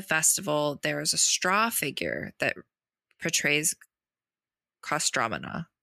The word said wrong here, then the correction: Kostroma: Why did I forget how to say this? Kostroma. There we go. I was like, storm. Kostroma. So the straw figure portrays Kostroma.